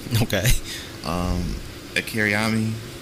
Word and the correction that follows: Toriyama: I know uh, I said it wrong again, but it's, it's whatever. – Toriyama.